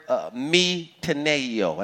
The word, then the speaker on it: metanoeo: Repentance here, folks, is a Greek word, metanoeo, and I'll spell it, M-E-T-A-N-O-E-O.